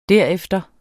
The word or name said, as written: derefter